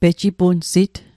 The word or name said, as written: pejipunsit